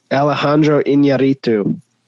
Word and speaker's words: Alejandro Iñárritu: Alejandro Iñárritu.